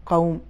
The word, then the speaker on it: قوم: کلمہ قوم واو اس میں ہے, ساکن ہے, اس سے پہلے والا قاف ہے جس پر زبر ہے, تو اس کا تلفظ اس طریقے سے ہے قوم.